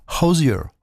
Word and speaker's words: Hozier: chanteur qui s'appelle Ozier. Alors, ça s'écrit Hozier. Ça se prononce pas du tout comme ça dans son pays, évidemment. C'est Hozier. Voilà.